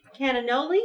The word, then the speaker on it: cannoli: And cannoli, is that what this is? – It's a cannoli.